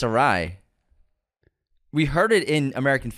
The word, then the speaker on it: Ari: Funny thing is, Anthony still says it incorrectly. – Thank you. – Though he claims James is wrong. Guys, it's Ari.